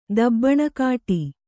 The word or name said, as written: ದಬ್ಬಣಕಾಠಿ